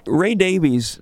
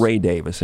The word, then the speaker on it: Davies: By the way, Ray Davies, that's the way most people pronounce it. We had Ray himself on the show. It's Ray Davis, in case anybody's going to complain.